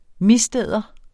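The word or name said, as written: MISDÆDERES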